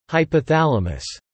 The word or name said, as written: hypothalamus